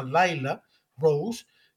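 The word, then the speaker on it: Lila Rose: Se escribe Lila, pero se pronuncia Laila Rose,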